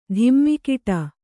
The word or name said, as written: ಧಿಮ್ಮಿಕಿಟ